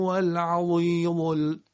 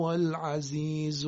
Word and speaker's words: العزیز: پس هیچ تغییری در اون زی فارسی ما نمیدیم در کلمه عزیز. یعنی اگر بگیم و هو العظیم، اینا دیگه غلطه. و هو العزیز الحکیم، همونطوری که زی فارسی رو تلفظ میکنیم.